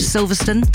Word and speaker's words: Silverstone: They're off to Silverstone. Did I say that right? – You did, yeah.